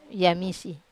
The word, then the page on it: amise: Wamesa